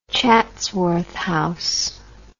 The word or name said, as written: Chatsworth House